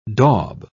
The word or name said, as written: daub